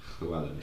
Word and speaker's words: Llewellyn: Llewellyn. Is that how you say it? – Yeah. – Yeah. Because it's like a chl and then a double L.